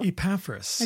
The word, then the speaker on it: Epaphras: but you just don't hear Epaphras a lot, and I can barely say his name, so Epaphras.